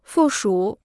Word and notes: 附属: fù shǔ

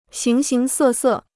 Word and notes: 形形色色: xíng xíng sè sè

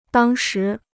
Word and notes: 当时: dàng shí

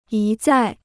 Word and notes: 一再: yī zài

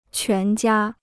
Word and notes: 全家: quán jiā

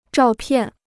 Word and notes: 照片: zhào piàn

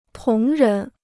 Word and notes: 同人: tóng rén

